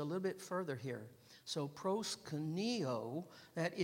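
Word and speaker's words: proskuneo (προσκυνέω): Accent on that O, proskuneo.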